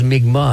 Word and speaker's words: Mi'kmaq: Puis, bon, avant, on disait Mi'kmaq, mais maintenant, on prononce Mi'kmaq.